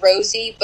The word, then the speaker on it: Rozey: TJ Rosie, but Rosie is spelled like R-O-Z-E-Y.